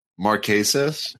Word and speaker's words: Marquesas: from Survivor Marquise. And, uh, she, she ah. just, she, did I say it wrong? Marquesas.